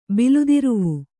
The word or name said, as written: ಬಿಲ್ದಿರುವು